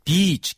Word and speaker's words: beach: 그래서 beach 길게 발음하셔야 돼요. B-E-A-C-H.